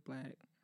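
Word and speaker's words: Blxst: His name is Black. That's not how it's spelled, though, but it's okay.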